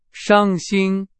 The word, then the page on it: 伤心: Chinese